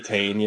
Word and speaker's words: Titane: I don't know how you say it. – Titane, yeah.